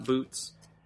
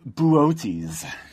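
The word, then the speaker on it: Boötes: remember how this is pronounced and it's not boots? – Bu- buotes. Buotes.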